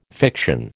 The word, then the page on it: fiction: American English